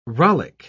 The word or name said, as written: rollick